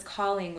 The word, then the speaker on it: Colling: Our last name is Calling, which is spelled C-O-L-L-I-N-G.